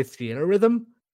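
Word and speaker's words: Theatrhythm: Fiat Rhythm. Did I say that right? Are you familiar with Fiat Rhythm? – I've never had to pronounce it out loud. I think maybe I'd say Theater Rhythm.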